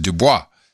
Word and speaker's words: Du Bois: Now, I've had friends who had that last name in Europe, and of course you would pronounce it Du Bois. Mm-hmm. Interestingly, when you said Du Bois, I was just like, that's it. You know, I took that as the official pronunciation.